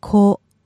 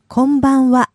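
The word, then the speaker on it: Konbanwa (こんばんは): Konbanwa. – Okay, very nice. And one time fast. – Konbanwa.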